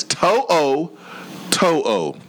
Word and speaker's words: To'o To'o: It's To'o To'o. To'o To'o. It's like your toe. Oh. To'o. – You confuse. You made it worse. – To'o To'o.